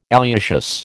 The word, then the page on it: alliaceous: American English